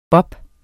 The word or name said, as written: bop